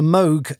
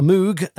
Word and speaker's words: Moog: the Moog as it's properly pronounced, right? The Moog as it's written, the Moog. That's right.